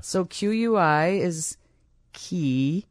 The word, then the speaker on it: quinoa: If you said to me, I'll give you $10,000 if you can spell quinoa correctly, I would lose $10,000. I couldn't spell it if it saved, to save my life. – Q-U-I-N-O-A? – Yeah.